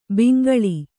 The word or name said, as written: ಬೆಂಗೊಳು